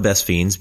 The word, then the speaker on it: Best Fiends: It's not Best Friends, it's like spelled like Best Friends, but without the R. Best Fiends. Best Fiends is a casual game anyone can play,